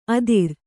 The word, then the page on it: ಅದುರು: kannada